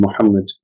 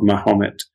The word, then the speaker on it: Muhammad: And the reason I do this is because, subhanAllah, he didn't even want to use the proper pronunciation of the name Muhammad. It was in use. Yeah, there's no excuse to say that it wasn't in use, but this is a very Turkish-esque or more European pronunciation of the name, Life of Mahomet.